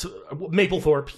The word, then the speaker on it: Mapplethorpe: Is it Mapplethorpe? – It's Mapplethorpe, yeah.